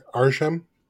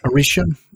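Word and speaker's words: Arishem: Oh, how do you say that? – As I'm saying, Arsham. – Arsham. – It's A-R-I-S-H-E-M. So I think it's Arsham. – Arsham.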